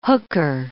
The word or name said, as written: hooker